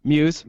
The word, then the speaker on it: Mewes: And how Jason Mayhew, is that how you pronounce his last name? Mewes.